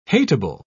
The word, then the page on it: hatable: British English